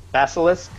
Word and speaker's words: Basilisk: I think a lot of the stuff in Team 7 with Basilisk, mm-hmm. did I say it right? – Yes. Yeah, you did. Good job.